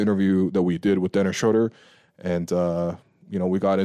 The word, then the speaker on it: Dennis Schröder: Dennis Schröder.